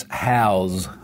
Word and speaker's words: Houzz: Now, one is HOWZ. I think that's how you pronounce it, H-O-U-Z-Z.